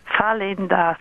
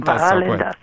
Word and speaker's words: Valendas: Habe ich es richtig gesagt? Nein, ist total falsch. – Ja, das ist Valendas. – Valendas.